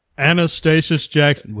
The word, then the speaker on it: Anastasius: It's like, how do you pronounce it? Anast. Do it again. Do it. Anastasius Jackson. Was that it? You got it.